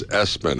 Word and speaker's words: ESPN: The name is Espen. How do you spell that? E-S-P-N is how you spell that.